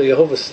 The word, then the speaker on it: Yehovah: So Yehovah's three syllables. Yehovah, Yehovah, Yehovah, Yehovah, Yehovah. Like 1, 2, 3, 1, 2, 3.